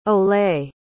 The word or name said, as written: olay